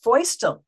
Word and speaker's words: Feustel: It's pronounced Feustel, rhyming with Joyce.